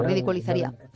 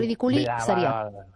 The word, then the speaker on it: ridiculizaría: Exacto, ridiculizaría, sí, es difícil de pronunciar. Piensa siempre en la palabra inicial, ridículo, entonces ridiculizaría. Cuando una palabra se te revela, es mejor dividirla en dos.